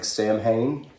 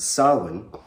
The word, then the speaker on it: Samhain: Samhain, which is how you pronounce the word that looks like Samhain, which you may have heard, S-A-M-H-A-I-N, pronounced Samhain, which is an old Celtic harvest festival.